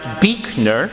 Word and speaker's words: Buechner: When you go to Buechner, here's what he says, and I quote, "Buechner is my name. It is pronounced Buechner.